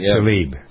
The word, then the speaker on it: Tlaib: and is it Talabi? I never pronounced her name right yet. I don't know. Tlaib. – Tlaib.